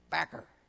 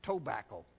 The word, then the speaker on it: tobacco: Here's the way you pronounce it in Kentucky. Backer. You all are saying tobacco. It's backer and it's over yonder.